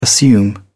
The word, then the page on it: assume: British English